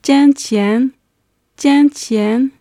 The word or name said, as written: jingqian